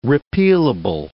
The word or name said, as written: repealable